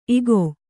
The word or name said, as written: ಇಕೊ